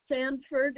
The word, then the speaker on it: Sandford: Sanford is the last name, S-A-N-D-F-O-R-D. – Okay, you said S-A-N-D? – F-O-R-D.